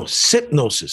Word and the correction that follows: synopsis: Let's see how you say it. Let's see. I'm going to find out how to say this word that I'm trying to say. You listen. You tell me. – Synopsis. – Synopsis. – Yes.